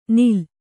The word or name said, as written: ನಿಲ್